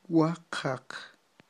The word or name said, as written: waqaq